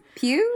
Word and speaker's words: Pugh: Pugh? That's how it happens? Pugh? – Okay, that makes sense.